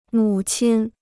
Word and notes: 母亲: mǔ qīn; mǔ qin

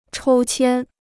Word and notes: 抽签: chōu qiān

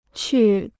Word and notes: 去: qù

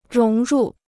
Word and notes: 融入: róng rù

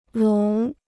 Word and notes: 聋: lóng

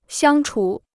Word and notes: 相处: xiāng chǔ

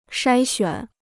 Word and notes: 筛选: shāi xuǎn

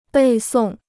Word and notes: 背诵: bèi sòng

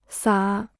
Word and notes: 撒: sǎ

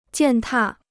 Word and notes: 践踏: jiàn tà

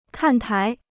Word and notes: 看台: kàn tái

